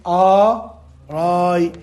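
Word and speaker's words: آراء: جيد قلبت الألف خلنا أطبق هسه ثم نقلب الهمزة وقلنا ثم نقلب الهمزة الثانية ألفا فيصبح الجمع آراي آراي شنو آ من شنو هي الهمزة تطلب آ كل همزة قرآن كل همزة وألف تنطق آ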